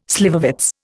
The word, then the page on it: slivovitz: Indian